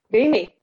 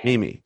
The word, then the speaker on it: Mimi: Mimi. Yeah. Mimi.